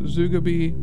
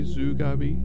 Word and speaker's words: Zugabe: Before that, you heard the song by the band Zugabee. That's the only way I could think that's possibly pronounced. Z-U-G-A-B, Zugabee, Zugabee.